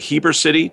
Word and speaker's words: Heber City: Folks, we're talking with our friend Darryl Bosshardt from Redmond, based in, is it Heber City? Do I pronounce that right, in Utah? – Correct.